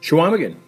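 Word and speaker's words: Chequamegon: Did I say that right? – Schwamigan. Yeah, right. Very good.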